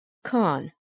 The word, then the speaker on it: Khan: The same goes for Khay, which is transliterated as KH with a line under it, as in entry number 26, Khan, meaning a chief. Again, if that is difficult, pronounce it as K.